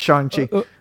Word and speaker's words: Shang-Chi: Yeah, because I didn't know if it was Shang-Chi or Shang-Chi, and they make it very clear in the movie. – And that's good. I'm glad because all of us are wrong because we were all saying it wrong. That's the first time I've tried to say it out loud since you texted it. So it's Shang-Chi. – But Sean, with the G at the end.